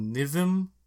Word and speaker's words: NXIVM: She was part of the, and I, forgive me, I think it's called NIVM. Is how you're supposed to pronounce them. It's like NXIVM.